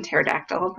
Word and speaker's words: pterodactyl: Of course, she didn't know about spelling and the letter P, the silent P in pterodactyl, but that's cute.